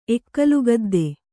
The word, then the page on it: ಎಕ್ಕಲಗದ್ದೆ: ekkalagadde